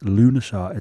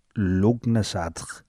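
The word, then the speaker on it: Lughnasadh: I found that this is how you pronounce it. Lunasar, it's spelled L-U-G-H, N for November, A-S-A, D for Delta, H. Lunasar. But to look at it phonetically, it says Lugnasadr. – Yeah. – I would say, but apparently, according to sources I looked at... – you pronounce it as Lunasar.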